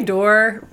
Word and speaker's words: Dore: It, uh, I say door. We my family says Dore, but I like Dore because I think that's actually what it was originally.